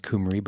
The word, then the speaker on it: Cymru: I'm sure it's pronounced Cymru, but it's like a Toyota Camry, but with a U.